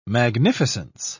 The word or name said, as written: magnificence